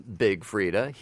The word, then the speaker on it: Big Freedia: So the I is silent, but it's definitely Big Freedia. – Okay, and from Big Freedia, here's a song called Excuse.